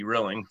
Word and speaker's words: Rilling: Well, it'd be B, Rilling for Bob. – Oh, Bob, it's Rilling. – It's Rilling. It's kind of like Thrilling without the TH.